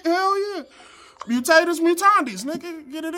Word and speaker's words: mutatis mutandis: I-S space M-U-T-A-N-D-I-S. – Mutatis mutandis. Wait, no, I said that wrong. – That almost sounded right, though. I almost believed it. – Mutatis mutandis? – Correct.